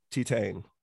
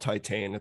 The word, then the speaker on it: Titane: So I just heard I listened to an interview today that it's not called Titane, it's called Titan, or how do we say it? – Titan.